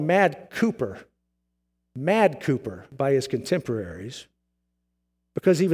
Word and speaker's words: Cowper: If you see his name, sometimes it looks like it's Cowper, C-O-W-P-E-R, but it's pronounced Cooper.